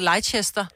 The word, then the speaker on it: Leicester: Det staves jo også sådan noget Leicester, men bliver bare udtalt Leicester.